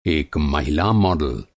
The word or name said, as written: मॉडल